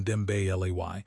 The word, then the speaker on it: Dembélé: It turns out that when players like Kylian Mbappé, pronounced Kylian Mbappé, and Ousmane Dembélé, pronounced Ousmane Dembélé, can generate power from almost no space, expected goals models simply stop mattering.